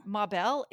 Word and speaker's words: Mabelle: Mabel, M-A-B-E-L-L-E. Is that how you say it? – Yeah, Mabel. Sure. Is her first name Ma and her last name Bell? – No, it's like Mabel.